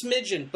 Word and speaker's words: Smidgeon: It is smidgen, but it is spelled S-M-I-D-G-E-O-N, and you'll see that reflected on...